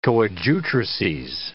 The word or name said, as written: coadjutrices